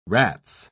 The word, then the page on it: rats: British English